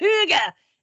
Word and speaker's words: hygge: It's called hygge, which is not how you think it would be pronounced when you're looking at it, because it's H-Y-G-G-E, but I guess they pronounce it hygge.